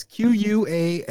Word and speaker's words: Qualley: No, it's Qualley. Qualley? Okay. It's Qualley.